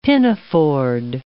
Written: pinafored

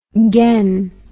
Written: gane

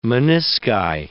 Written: menisci

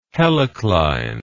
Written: helicline

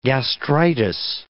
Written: gastritis

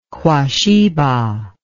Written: quasheba

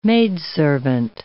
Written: maidservant

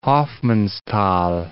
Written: Hofmannsthal

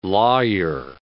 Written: lawyer

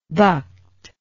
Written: bucked

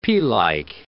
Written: pealike

